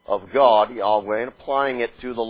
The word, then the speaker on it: Yahweh: Yahweh. And, or Yahweh may be the better pronunciation of it. But Yahweh, more commonly, or Yahweh, certainly not Jehovah. Alright? Not that.